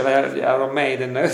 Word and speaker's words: Iron Maiden: Io, io, a me mi hanno preso in giro a scuola ogni volta che pronunciavo male il, il nome, Maiden, Maiden, invece Iron Maiden,